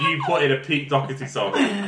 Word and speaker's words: Doherty: Pete Doherty, or Doherty as the Americans pronounce it.